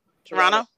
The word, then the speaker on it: Toronto: But when we get there, we must pronounce Toronto without the second T, because according to people from there, the second T stands for tourist. – Oh. – So Toronto? – Toronto. – Toronto? – T-O-R-O-N-N-O.